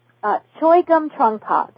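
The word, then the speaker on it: Chögyam Trungpa: Chungpa, I can't pronounce his name, Chungpa. Amazing book on symbolic warriors. And in the book, Choegum Trungpa, there we go.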